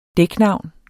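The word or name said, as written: dæknavn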